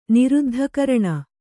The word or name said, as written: ನಿರುದ್ಧಕರಣ